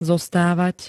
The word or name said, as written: zostavat